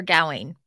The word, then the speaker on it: Gawain: It's Sir Gawain. And this is, he's one of the Knights of the Round Table. Gawain is his, can pronounce it Gawain as well.